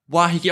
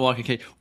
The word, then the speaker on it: Waiheke: Waiheke. Waiheke. I want to say Waikiki, but it's definitely not Waikiki.